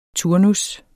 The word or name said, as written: turnus